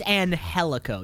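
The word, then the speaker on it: Angélico: I think it's Angelico. Yeah, it's a silent G.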